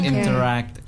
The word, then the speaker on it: interact: Nge-stressin bagian act-nya di terakhir itu. Jadi interact gitu. – Interact. Berarti memang stress word-nya ada di act-nya. – Iya, stress syllable-nya, suku katanya itu interact-nya di akhirannya begitu.